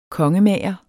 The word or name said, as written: kongemager